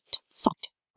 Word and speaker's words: Zeit: The letter Z sounds like ts. So Zeit, meaning time, is pronounced t-zeit.